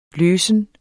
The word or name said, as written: løsen